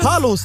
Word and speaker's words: Phallus: Palus, doch. – Palus. – Palus. P-H-A-L-L-U-S.